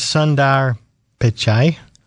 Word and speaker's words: Sundar Pichai: Okay, which is Sundar Pichai.